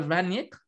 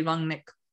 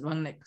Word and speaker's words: Rangnick: By the way, for those who don't know, it's not Ralf Rangnick. I have it on good authority from some German people. That's actually Rangnick. That's how you pronounce it, Rangnick. So not Rangnick, as some English commentators mispronounce it. It is actually Rangnick.